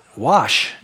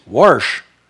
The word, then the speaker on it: wash: How do you pronounce the word WASH? It's funny. Wash. It's wash. That's how I always pronounce it, wash. Then you hear some people from back east, "Warsh." War, there's no R in there. Warsh? That's just weird to me.